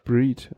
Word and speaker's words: Breed: Ähm, oder Breed, wie spricht man es aus? Ist es Holländisch, ist es Englisch? Breed, okay.